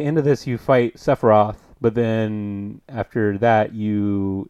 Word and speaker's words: Sephiroth: To be honest, Sephiroth has always been a quote-unquote good guy. – Just because you were a bad guy doesn't mean... Wait. Oh, yeah, I was saying it right.